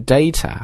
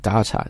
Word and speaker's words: data: 那英式英语呢除了读 data 还可以读 data，把这个 A 读成 R 这个音啊 data。